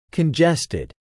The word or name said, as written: congested